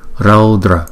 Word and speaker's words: raudra: Chữ au chúng ta đọc là au, không? Raudra, hum, hum. Đọc chữ hu trước rồi đóng chữ u lại. Hum, phật, phật. Chữ t cong lưỡi lại chúng ta búng ra. Tờ, tờ, phật, phật. Chúng ta chú ý là chữ Âu là một nguyên âm dài, đọc là Âu, Âu, Âu.